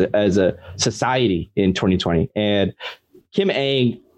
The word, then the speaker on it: Ng: Kim Ng. It's pronounced Ang. I had to look it up.